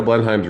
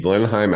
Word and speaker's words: Blenheim: I have received Blenheim apricot labeled Blenheim. I say it that way because although we mostly say Blenheim, it's spelled Blenheim.